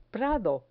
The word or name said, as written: prado